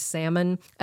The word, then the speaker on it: salmon: Although the questions, to be fair, range from stupid easy things like, what's the silent letter in the word salmon?